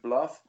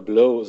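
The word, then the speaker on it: Blough: Heißt der Bluff? Man könnte unseren Schotten-Experten fragen, ist ja eigentlich auch fast schottischer Name, Bluff. Ich würde ihn so aussprechen, David. Aber manche, also ich habe jetzt auch oft gehört in irgendwelchen Videos, dass er Blau, oder Blow so ausgesprochen wurde David, von Amerikanern.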